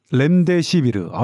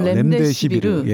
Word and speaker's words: 렘데시비르: 렘데시, 렘데시비르. – 아, 네. – 발음할 때마다 어려워요. – 렘데시비르.